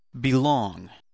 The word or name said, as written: belong